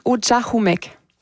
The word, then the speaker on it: Ujahumek: On le prononce Ujahumek. – Ujahumek.